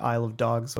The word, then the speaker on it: Isle of Dogs: And I guess that's the thing that I really enjoyed about Isle of Dogs was, did I say it right? – Yes.